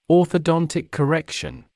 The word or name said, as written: orthodontic correction